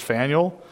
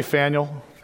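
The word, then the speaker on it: Phanuel: We're going to get to this guy's name, Anna's father, and the way it's pronounced, at least the way I'm going to pronounce it, is Faneuil. Because I like Faneuil Hall out in Boston, and when I saw it, I thought of that. So when we get to it, just say Faneuil. Faneuil, you're good to go, because it's just a weird P-H-A-N-E-U-L, I think is how it's spelled.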